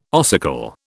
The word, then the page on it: ossicle: British English